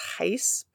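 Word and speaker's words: Gies: Her name is M-I-E-P-G-I-E-S, but it's Heiss pronounced from what I saw.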